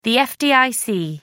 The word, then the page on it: fdic: American English